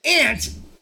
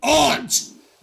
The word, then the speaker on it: aunt: That just proves that ant, not aunt, is the correct way to say that word.